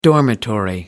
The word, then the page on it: dormitory: American English